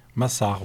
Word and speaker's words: Massar: Et c'est, alors, je vais te laisser le prononcer. Massar? – Massar, oui.